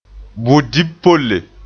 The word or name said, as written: budib-polle